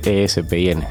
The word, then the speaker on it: Espin: Sí, generalmente casi todo el mundo piensa que es DJ Spin, como se escribe Hero en inglés, digamos. – Sí, eso es. – Y realmente es con la E delante. ESPN.